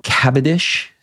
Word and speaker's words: Cavendish: Let's go back to England, to Cavendish. Is that how you say it? Yep.